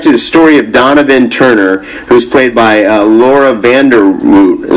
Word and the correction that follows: Vandervoort: Am I pronouncing that right? – Vandervoort. – Okay, it's a hard one there.